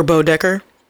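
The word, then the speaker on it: Bodecker: And then the very handsome Sebastian Stan, who plays Sheriff Leave Broaddecker. – Bucky Barnes. – Or Bo Decker. I said that wrong. There was no R there.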